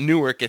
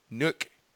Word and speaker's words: Newark: Well, it's like Newark. It's not Newark. It's Nook.